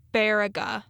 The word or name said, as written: Baraga